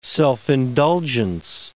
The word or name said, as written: self-indulgence